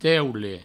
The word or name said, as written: teule